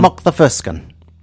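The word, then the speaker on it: Makthaverskan: mock. It's Mock the Furskin. – Emphasis on. – Yes, emphasis on the first and third syllables. Yes. And the M-A-K is an M-O-C-K sound to us.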